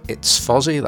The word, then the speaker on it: ItsFozzy: If you do a seller search for It's Fozzy, that's ITSFO.